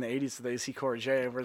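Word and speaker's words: Courrèges: Can you just look up AC Corriger? Corriger is spelled in a French manner, so C-O-U-R-R-I-G-E-S. – Oh, like running? – Yeah. – Courier? – Yeah, there it is.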